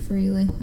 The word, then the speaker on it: Frehley: Frehley.